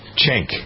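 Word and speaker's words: Cenk: Zenk Yeager. I never can pronounce his name. I think it's Jenk. Jenk. Yeah, anyway. – I think it's Chenk. – Chenk. Okay. Anyway,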